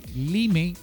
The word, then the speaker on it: Lyme: Digámoslo, como está escrito, Lyme.